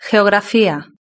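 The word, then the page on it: geografia: Spanish (Spain)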